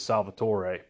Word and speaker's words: Salvatore: Salvatore, or Salvatore. I believe the correct pronunciation is Salvatore, but he is the writer, the creator of the dark elf character Dritzt, and I own every book that Dritzt is in.